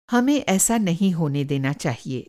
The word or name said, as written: होने देना